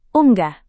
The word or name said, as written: unggah